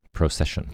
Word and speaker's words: procession: Procession. – Procession. – It's not a hard C, it's a soft C.